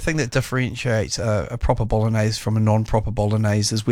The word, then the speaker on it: bolognese: In fact, I think you've overpronounced the G there. – Have I? – I think so. Yeah. Bolognese.